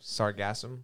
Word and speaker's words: Sargassum: It's S-A-R-G-A-S-S-U-M. – Sargassum? Sargassum? – Sargassum.